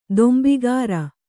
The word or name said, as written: ದೊಂಬಿಗಾರ